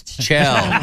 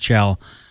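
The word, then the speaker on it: Kjell: No, it's Chell. – That's exactly what it is. It's just, it's hardcore Norwegian. – Uh-huh. – And, you know, it's, I have a... It's K-J-E-L-L. It's pronounced Chell.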